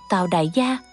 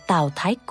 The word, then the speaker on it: Tào Đại Gia: Sau khi nghiên cứu kỹ thì mới phát hiện chữ Đại này đọc là Thái, giống chữ Thái trong Thái Thái. Còn chữ Gia là cô, là chữ cô trong từ cô thúc, nên phải đọc Tào Đại Gia thành Tào Thái Cô.